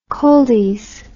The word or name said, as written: coldies